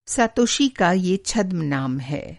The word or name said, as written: छद्म-नाम